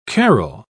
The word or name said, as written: carol